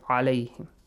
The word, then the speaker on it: عليهم: So alayhim, not alayhim. Alayhim has another meaning. Alayhim, another meaning. So two different meanings. So you have to say عليهم.